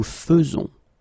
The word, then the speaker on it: faisons: because you don't pronounce it E as normally you should, but you will pronounce it like E. Nous faisons, okay, it's really f, f, f, nous faisons, okay, you see it with e, but then you pronounce it f, nous faisons.